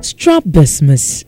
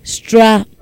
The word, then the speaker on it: strabismus: Strabismus. Say it after me. Strabismus. And it is spelled as S-T-R-A-B-I-S-M-U-S. S-T-R-A-B-I-S-M-U-S. M-U-S. Strabismus.